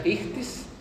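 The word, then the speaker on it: Ictis (ἰχθύς): Que se escribe Ixoye, se pronuncia Ictis en griego, que son las siglas de las características de Jesús.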